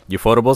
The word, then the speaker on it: Ufotable: My number two is, I don't know if I'm going to pronounce it correctly, but Ufotable. – Ufotable is correct.